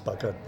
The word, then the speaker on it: Bargoed: Uh, so I was born up there outside Bargoed, pronounced buggered by the locals.